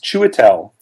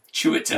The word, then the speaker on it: Chiwetel: His name, by the way, his first name is pronounced Chiwetel. – Chiwetel. – His last name is pronounced Ejiofor. – Ejiofor.